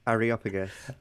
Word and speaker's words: Areopagus: Areopagus.